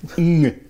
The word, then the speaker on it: Ng: Charles Ng. – Ng. Det är ett vanligt Ng-ljud där.